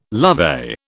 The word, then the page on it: lovey: British English